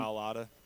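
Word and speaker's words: Mailata: Malata.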